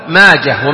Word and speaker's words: ماجه: قال وقال ابن منده، وكان ابو داود ابن منده، منده دائما بالهاء، منده وقفا ووصلا، اسم فارسي، منده، منده وسيده، ابن سيده ايضا بالهاء وقفا ووصلا، وماجه، هذه الثلاثه كلها بالهاء، ماجه ومنده وسيده، ما يقال ماجه منده لا خطأ، لأنها أسماء فارسية تنطق بالهاء وقفا ووصلا.